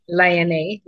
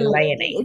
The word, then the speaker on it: Léonie: It's Léonie. Léonie. Yeah, Léonie.